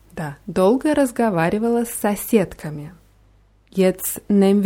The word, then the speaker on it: долго: Dolga wird mit zwei O's geschrieben, in der Mitte und am Ende. Und das letzte O wird eher wie A ausgesprochen. Dolga.